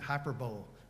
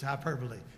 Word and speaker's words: hyperbole: I used to think you pronounced it hyperbole, but it's not. It's hyperbole.